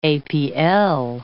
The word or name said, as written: APL